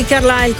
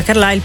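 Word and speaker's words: Carlisle: Brandi Carlile, che non è parente di Belinda Carlile, perché in quel Carlile c'è una S in più che non si pronuncia, invece questa non c'è la S.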